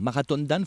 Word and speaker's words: Marathon Dan: Alors, on va dire Marathon Dan, il faut le prononcer à l'anglaise.